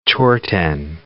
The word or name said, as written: chorten